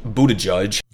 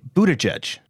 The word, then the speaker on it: Buttigieg: All right, so Pete Buttigieg. – Okay. So you're not quite there. It's Pete Buttigieg.